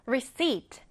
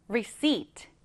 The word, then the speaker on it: receipt: 얘는 발음하실 때 receipt, P가 무음이에요. 없는 것처럼 발음합니다. receipt, receipt.